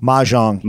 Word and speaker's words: mahjong: Uh, the uh, theme on Monday was uh, mahjong tile. Is that how you say it? Mahjong. Mahjong. – Mahjong yeah, yeah.